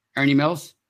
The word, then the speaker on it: Ernie Mills: Wait, say his name again, please. – Ernie Mills. Did I say it wrong? – No, you said it right.